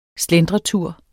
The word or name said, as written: slentretur